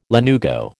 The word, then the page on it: lanugo: American English